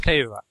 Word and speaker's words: teva: Well, it's already up by the time people are hearing this, but that says that I correct myself on the pronunciation. It's teva.